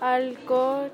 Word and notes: Allqo: quechua

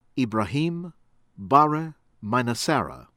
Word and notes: Ibrahim Bare Mainassara: American English

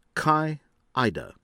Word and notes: Kai Eide: American English

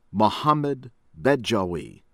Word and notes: Mohamed Bedjaoui: American English

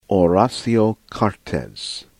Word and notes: Horacio Cartes: American English; from Paraguay

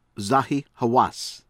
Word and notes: Zahi Hawass: American English

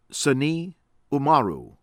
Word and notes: Seyni Oumarou: American English

